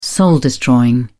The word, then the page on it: soul-destroying: British English